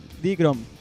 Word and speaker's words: Dikron: Sí, Dikrom. Se escribe D-I-K-R-O-N. – Bueno. – ¿Lo puedes pronunciar en español o en inglés? – N al final. – Exacto. – N de niño. – N de nene.